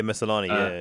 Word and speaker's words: Maslany: How to pronounce her name? – I can't remember. – Tatiana Mansley. – Yeah. – Maslany. – Yeah, Maslany.